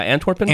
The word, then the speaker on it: Antwerpen: No, this is Guinness Antwerpen. Is that how you say it? – It sounds like, yeah, Antwerpen.